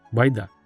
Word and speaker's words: байда: Шкура у этой байды была такая гнилая, что ее можно было проткнуть пальцем, так как она больше заслуживала название с ударением на последнюю букву – байда.